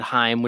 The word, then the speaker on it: Haim: I don't know what else to say about this band, Haim, which rhymes with time. So that's how you know you're saying it right.